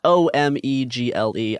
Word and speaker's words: Omegle: I never know how to say this one. Omogli. – I've never heard of it. I have no help for you here. – People will know what I'm talking about. It's this randomized chatting app that does video chatting with the randomized users. It's Omegle.